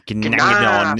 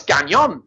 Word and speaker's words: Gnagnon: It's like Gnagnon. Yeah. – I initially saw it and thought, oh, it's Gagnon, because obviously, but it's, and then I was like, no, the end's in the wrong place.